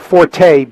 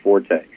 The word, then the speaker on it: Forte: Forte, because that's the way the owners want it pronounced. – Okay. All right, Forte.